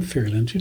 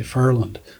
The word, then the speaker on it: Ferryland: If you're from Ferryland, you don't say Ferryland; you say Furland.